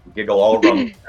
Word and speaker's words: Gigelorum: So I'm going to talk about, I'm going to pronounce it the Giggle Orum, but it's GIGELORUM. Giggly Orum? I'm not sure. – Giggle Orum sounds right to me on that, so.